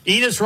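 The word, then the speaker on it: Enes: Enos rhymes with penis.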